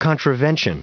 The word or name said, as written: contravention